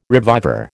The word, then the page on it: reviver: American English